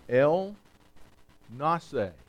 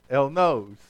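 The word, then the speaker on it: El Nasa: The name is El Nase. I know it looks like nose. El Nose. Okay? El Nase. And I had to listen to someone pronounce it for me because I was pronouncing it El Nose.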